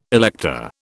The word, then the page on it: elector: British English